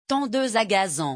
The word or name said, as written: tondeuse